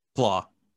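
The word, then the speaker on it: Klaue: Claw.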